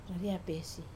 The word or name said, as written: raria pesi